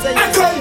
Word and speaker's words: Akon: Oui, il faut le prononcer aussi à la française, je veux dire. Oui, vit dans un pays de droit et de valeur. C'est Akon que ça se prononce et c'est ça, Akon.